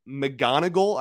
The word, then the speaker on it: McGonigle: So with the 37th pick, the Tigers selected shortstop Kevin McGonigal, I believe is how you pronounce his last name, who is from prep bat from Pennsylvania.